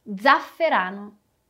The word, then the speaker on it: zafferano: O primeiro e o segundo Z tem um som de abelhinha aqui, né? Então zen e de novo zero. Zenzero. Vamos ver outro exemplo. Zafferano, zafferano, que é açafrão. De modo geral, podemos usar essa pronúncia de abelhinha, com as palavras que começam com a letra Z. Assim como a gente viu em zenzero e zafferano.